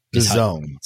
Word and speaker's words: DAZN: I call it DAZN. Is it not pronounced that? – What's the spelling? Tell everybody what the spelling is. – DAZN. – DAZN. – DAZN. – Is how you pronounce that brand.